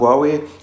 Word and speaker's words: Huawei: an Huawei, so spricht man es richtig aus,